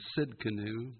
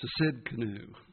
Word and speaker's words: Tsidkenu: It's kind of a hard name to pronounce if you put the T in there. If the T's not silent, it's Tsidkenu, but the T's there for a reason. It's Tsidkenu, I guess.